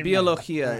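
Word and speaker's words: biología: But biologia is how you say it in Spanish.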